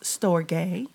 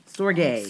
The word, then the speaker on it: storge: It's actually pronounced storge. – Storge. – Yes. Storge.